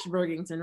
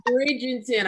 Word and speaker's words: Bridgerton: You watch Bergington, right? – Yes, yes, yes. – I don't know if I'm saying it right, y'all. – Oh my God. Yes, that guy, Lady Bridgerton. – Bridgerton.